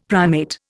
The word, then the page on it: primate: Indian